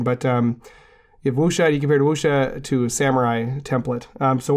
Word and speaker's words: wuxia: I've usually seen it in print, so with my due deference to people who actually speak Chinese, wuxia is I'm pretty sure how it's pronounced.